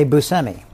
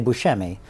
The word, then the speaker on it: Buscemi: I say Buscemi. – You do. – Growing up, my family said Buscemi. – Buscemi, that's a new one to me. – That, I think my dad came up with that. Yeah. But the correct, Sicilian pronunciation is Buscemi. So when people say Buscemi, that's very close to how I think what the real pronunciation is. So I don't usually correct people if they say Buscemi.